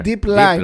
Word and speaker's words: Deep Line: Και ο Κώστας Τσίλης γράφει και τελικά τι είναι αυτό το Dom Line Playmaker. – Deep Line. Deep line. – Α, δεν διαβάζεται γι' αυτό. Deep.